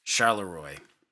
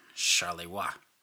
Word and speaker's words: Charleroi: In the last episode, I pronounced the town as Charleroi, but it is actually pronounced Charleroi.